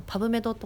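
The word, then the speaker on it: PubMed: だけど今ネットがあるので、例えば、理系の人だったら使ったことあると思うんですけど、パブメドとかってあるんですけども、パブメドって読むのかな、ずっとパブメドって読んでたんですけど、ありますね、多分パブメドパブメドですね、ウィキにそう書いてあるんで。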